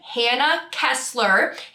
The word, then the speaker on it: Hannah Kessler: You can look up my name, Hannah Kessler. Hannah spelled the same ways forwards and backwards, Kessler with one S.